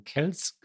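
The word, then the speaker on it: Kelce: Wir haben letztes Mal darüber diskutiert, spricht man Kells eigentlich Kells oder Kelsey aus und haben lange hergeleitet, warum Kells gesagt wird.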